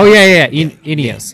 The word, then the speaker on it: Ineos: Man, Team Enos now, right? Enos. – Oh, Ineos. – Ineos, okay.